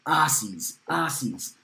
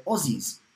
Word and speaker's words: Aussies: It's spelt Australia. It's got an S in it. But down under, we say Aussies, like Ozzy Osbourne, Ozzy Newsome. For us, it is with the Zeds.